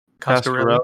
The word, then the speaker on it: Coscarelli: Coscarelli.